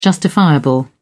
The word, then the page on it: justifiable: British English